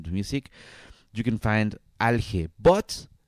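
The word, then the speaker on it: Alhe: The artist is called Alge. That's how I pronounce it in Spanish. But I don't know how would I pronounce this in English. Like Alge, it's A-L-H-E. A-L-H-E.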